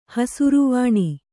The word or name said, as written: ಹಸಿರುವಾಣಿ